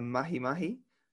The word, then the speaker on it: mahi-mahi: You've also recorded a really cool clip that I saw of a false killer whale attacking a mahi-mahi. Is that how you pronounce it? – Yep.